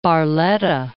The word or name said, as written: Barletta